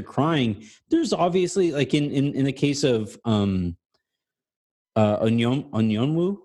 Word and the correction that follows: Okongwu: is that how you say it? – Okongwu. It's Onyeka Okongwu.